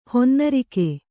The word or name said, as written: ಹೊನ್ನರಿಕೆ